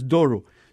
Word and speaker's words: Doru: It's very hard to pronounce Doru.